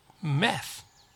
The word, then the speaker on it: math: teaches Math, math。はい、 M と A の発音、まじゃなくて meh、 meh。 th はこの場合は濁らない、ずっと濁らない、舌を挟んで抜きます。ちょっとね、軽くですよ。 meth, meth, meth。